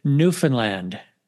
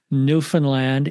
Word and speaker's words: Newfoundland: We're going all the way to Newfoundland.